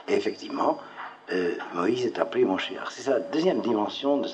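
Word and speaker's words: Moshiyar (מושיע): En hébreu, cela se dit Moshiyar, non pas Mashiyar, mais Moshiyar.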